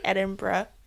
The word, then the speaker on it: Edinburgh: It's not Burrow. – Fuck. – I'm like quite certain it's like Edinburgh.